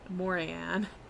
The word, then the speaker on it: Morrigan: Morian it's spelled Morrigan, but I've read that the G is silent, so I'm gonna say Morrigan. Morian.